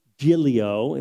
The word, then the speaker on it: Giglio: and try to find one by Louis Giglio. It's like G-I-G-L-I-O is how to say his name.